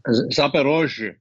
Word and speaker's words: Zaporozhye: It's also Kherson and help me out with that pronunciation. Z Zaporozhye. – Yeah, like what he said.